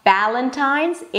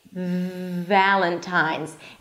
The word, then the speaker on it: Valentine's: Okay, so it's not Valentine's, it's Valentine's. And to make sure that you're actually saying it correctly, hold out the V sound. If you can't hold it out, then it means that you're making a B sound.